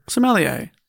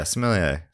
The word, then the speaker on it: sommelier: There's no L in it, right? Sommelier? – No, there is, I think. – Okay. – Sommelier. – Yeah, sommelier. Sometimes those French words have a silent L. Not in this case.